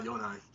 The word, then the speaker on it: Jotunheim: Because and most of the time in German, Dutch, and I guess you could say a Scandinavian language, the J is going to sound like Y. – Right, of course. Jotunheim.